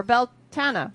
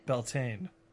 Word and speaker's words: Beltane: Beltane, or Beltana, or Beltina, or however you like to pronounce it. – Beltane.